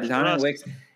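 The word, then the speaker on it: Dontayvion Wicks: Deontavon Wicks, is that how you say his name? – Yeah. – Am I saying it right? All right, Deontavon Wicks.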